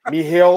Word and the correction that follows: Mikhail: I would say Mikhail Oleksaychuk, yeah. But yeah, not Michael, but Mikhail or something.